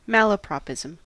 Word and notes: malapropism: American English

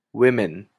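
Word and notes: women: Canadian English

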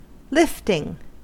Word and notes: lifting: American English

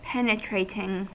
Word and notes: penetrating: American English

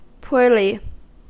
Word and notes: poorly: American English

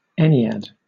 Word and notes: ennead: British English

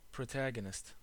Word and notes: protagonist: American English

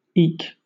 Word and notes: eke: British English

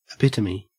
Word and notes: epitome: Australian English

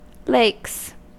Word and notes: lakes: American English

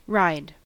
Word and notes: ride: American English

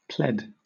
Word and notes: pled: British English

